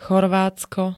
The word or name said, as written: Chorvátsko